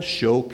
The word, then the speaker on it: shoked: The word for almond, which is shaked, and the word for watchover, shoked, are exactly the same identical word. There it is in Hebrew. Exact same identical word, no difference in spelling. You pronounce it shaked for almond, shoked for watchover.